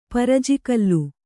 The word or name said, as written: ಪರಜಿಕಲ್ಲು